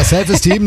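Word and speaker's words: Self Esteem: The Offspring, Self Eastern. – Ja, die Aussprache ist ein bisschen... – Steam ist Steam. – Ja. Ja, ist richtig. – Ja, Self-Esteem nehmen wir auch.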